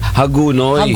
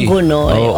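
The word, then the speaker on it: Hagonoy: Pero yung mga local doon, pati yung mga kakilala kong reporter na taga doon, ganun daw talaga pala ang pronunciation. Hagunoy. – Hagunoy.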